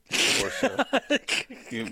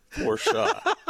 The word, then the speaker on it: Porsche: Porsche. – It's pronounced Porsche. Porsche. – It's okay. – The only thing worse than someone owning a Porsche is calling it Porsche.